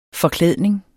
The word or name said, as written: forklædning